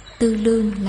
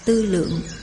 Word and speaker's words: tư lượng: Nếu lấy tàn làm ngã, tư lương là chữ mà các thầy đọc như vậy, thật ra phải đọc là tư lượng.